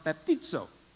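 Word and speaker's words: baptizo (βαπτίζω): But the English letters would look like this, and it would be pronounced baptizo.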